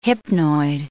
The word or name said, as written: hypnoid